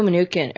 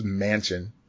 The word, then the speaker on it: Manchin: And fucking Joe Manukin, or Manchin, like, whatever the fuck. How you pronounce that fucking dude's last name? – Why do they always have supervillain names? Manchin.